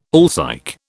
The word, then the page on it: alsike: British English